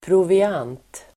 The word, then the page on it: proviant: [provi'an:t]